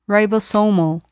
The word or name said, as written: ribosomal